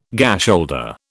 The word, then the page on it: gasholder: British English